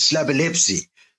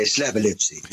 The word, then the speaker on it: Slabolepszy: But the correct pronunciation is Slabolepszy. If you can say epilepsy, you can say Slabolepszy. There's no K. It's as you read it.